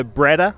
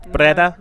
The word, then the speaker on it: Breda: We rode to Breda on the first day. Was that my pronunciation, if I finally got it right? Breda? – No, Breda.